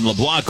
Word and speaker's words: LeBlanc: Another 0-2 from Mercurius to LeBlanc. Here it comes. Misses high and outside. L-E-B-L-A-N-C, the spelling, but it is pronounced LeBlanc. You do not pronounce the C on his name. He's a Louisianan. And you know, the E-A-U-X is down there and everything.